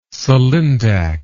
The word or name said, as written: sulindac